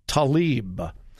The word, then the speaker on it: Tlaib: And I looked up the proper pronunciation, and this is how she pronounces it. Rashida Tlaib. She is the freshman representative from Michigan and a Muslim.